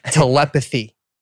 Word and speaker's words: telepathy: I can't pronounce that word. Telepathy. – Telepathy. – 한국에서는 텔레파시라고 하니까 제가 이렇습니다.